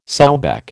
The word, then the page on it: sowback: American English